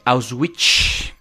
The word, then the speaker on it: Auschwitz: Obviamente, como tú lo mencionaste en el campo de concentración de Auschwitz, que me sorprende que lo hayas pronunciado bien para tu nivel de dialecto y, cultura.